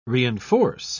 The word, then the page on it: reenforce: British English